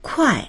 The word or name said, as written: kuai4